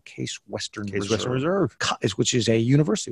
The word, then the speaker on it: Case Western: It's not, okay. It's not Quesney Western.